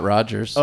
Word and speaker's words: Rodgers: My name's Scott Rogers. – Oh, okay. How do you spell that? – R-O-D-G-E-R-S. It's like the Dodgers, but with an R at the beginning.